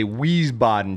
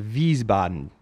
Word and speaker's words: Wiesbaden: I was stationed in America's, you know, you would say Wiesbaden, Germany, but that's wrong. I was stationed in Wiesbaden.